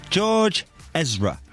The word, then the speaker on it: George Ezra: Am I saying that correctly? George Ezra. – Yeah. – Yes? – Yeah.